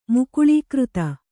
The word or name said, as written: ಮುಕುಳೀಕೃತ